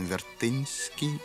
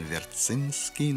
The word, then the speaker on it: Вертинский: Поляки говорят «пан Вертинский» или «пан Верцинский», но это уже вопрос произношения.